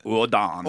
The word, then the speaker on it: Odin: Auden. We got Auden. The death, we got Auden there. – We pronounce it Odan.